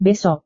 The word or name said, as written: besok